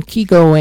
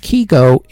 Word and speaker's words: Kygo: For the record, it's pronounced Kygo in Norway, where he's from, so we go with that instead of the Kygo, like everybody else does, which is more of an Americanized version, just for the record.